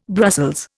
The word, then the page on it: Brussels: Indian